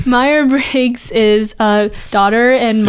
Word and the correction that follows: Myers: While we're on the topic of mispronouncing things, you've said Meyer Briggs. – It's not Meyer? – It's Myers. – Myers Briggs.